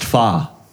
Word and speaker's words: Pho: Pho. Of course, I was told that it's pronounced fa.